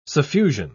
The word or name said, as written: suffusion